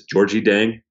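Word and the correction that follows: Gorgui Dieng: I'm pretty sure it's Georgi Jang. It changes when you're watching away broadcasts, but I think that's the proper pronunciation. – I'm looking it up now on Basketball Reference, and you are correct. Georgi Jang.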